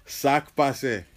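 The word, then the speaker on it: sak pase: So how do you pronounce it again? – Sac passé. – Sac passé. – There you go.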